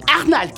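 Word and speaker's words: Arnall: Euh, anal. – Euh, anal qui s'écrit. Arnal qui s'écrit.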